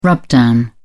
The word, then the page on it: rubdown: British English